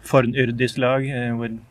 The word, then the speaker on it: Fornyrðislag: Yeah, Terry uses modern Icelandic pronunciation of the Old Norse terms. So Fornyrðislag with the Norwegian pronunciation.